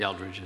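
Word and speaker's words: Eldredge: I said that right? – Eldridge.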